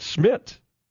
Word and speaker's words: Szmytz: This kicker, if I'm not mistaken, and again, we're still in the Google free zone, has two Z's in his name, and I think both are silent. Andre Smith, spelled S-Z-M-Y-T-Z. – But maybe the Z at the end? – You just heard him pronounce it Smith.